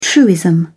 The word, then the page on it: truism: British English